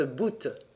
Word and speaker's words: bout: Un cordage, on prononce bout.